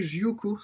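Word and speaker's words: Jyocho: J'ai découvert le groupe de rock progressif japonais Jyoko, ça s'écrit J-Y-O-C-H-O, c'est vraiment bien.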